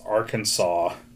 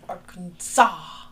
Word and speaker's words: Arkansas: It is strictly prohibited to pronounce Arkansas incorrectly per state code. The only acceptable pronunciation is in three syllables, which the final S is silent and the A in each syllable with the Italian sound.